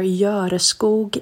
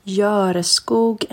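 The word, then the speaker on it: Jöreskog: The proper pronunciations are Jarskog and Sarbom. Jarskog and Sarbom. I don't know how many times we have to go over this.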